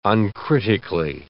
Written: uncritically

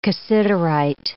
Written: cassiterite

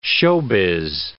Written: showbiz

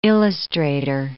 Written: illustrator

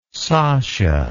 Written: sasha

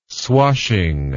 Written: swashing

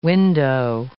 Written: window

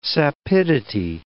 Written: sapidity

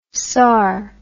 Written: sar

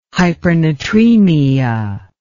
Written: hypernatremia

